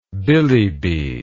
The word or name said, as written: billibi